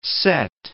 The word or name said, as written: sett